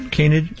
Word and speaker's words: canid: Canid? – Canid, yes.